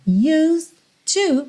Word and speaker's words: used to: Então, when your legs don't work like they used to before, use to, você já junta use to, o E de use tá pintadinho de cinza, ou seja, não é pronunciado, você não vai dizer use it to, use to, vai do S direto pro D que já vira T para ligar no to, use to before, before, or before, if you prefer.